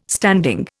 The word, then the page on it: standing: Indian